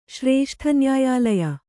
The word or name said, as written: ಶ್ರೇಷ್ಠನ್ಯಾಯಾಲಯ